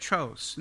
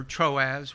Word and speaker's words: Troas: These men going ahead waited for us at Tros. Now remember, or Troas, depending on how you want to pronounce that, that's where they picked up Luke to begin with before they started this third missionary journey, or when they started that.